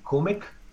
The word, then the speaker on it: Komic: Yeah, let me pull that K-O-M-I-C. Up. How do you spell it, again? – K-O-M-I-C, Komik.